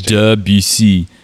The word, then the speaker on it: Debussy: The first time someone told me about Debussy, which is, I think that's basically how you pronounce it in French, like French, Debussy.